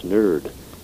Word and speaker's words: nert: It pops up in Irish N-E-R-T, meaning powerful or hero. Nert, it's actually pronounced nerd in Irish, and this is the source of the English term.